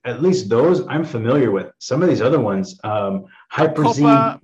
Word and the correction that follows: Huperzine: Bacopa, Huperzine A, Vinpacetine.